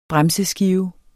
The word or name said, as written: bremseskive